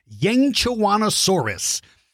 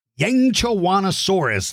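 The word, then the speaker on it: Yangchuanosaurus: It is Yangchawanosaurus. Yangchawanosaurus.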